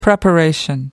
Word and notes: preparation: American English

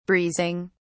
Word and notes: breezing: British English

